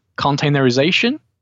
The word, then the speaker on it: containerization: Containerization, is that correct? – That's right.